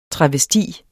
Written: TRAVESTIEN

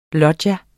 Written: LOGGIAERNES

